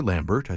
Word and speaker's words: Lambert: Anna Karenina, Constant Lambert, the composer, and or Lambert, I suppose, the British pronunciation, the love scene going back to 1948.